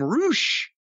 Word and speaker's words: Rush: and here comes the debut of Rush. But they were calling him Roosh. But it's spelled R-U-S-H.